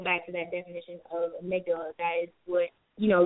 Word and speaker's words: Amygdala: How do you say that word? – Amygdala.